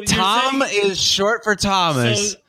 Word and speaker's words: Thom: T-H-O-M. How's that pronounced? – That's just Tom. – That's fucking Tom.